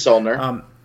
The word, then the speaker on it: Saulnier: It was the directorial debut, I believe, by, I'm going to butcher his name, Jeremy Saulnier. – You're right. It's Saulnier.